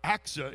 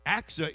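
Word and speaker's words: Achsah: And so here's Caleb, and he said, I tell you what, I'm going to give my daughter, Aksa is the way you pronounce it, Aksa in marriage to the one who attacks and captures Kirith Sepher.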